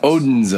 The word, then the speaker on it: Odense: Odense.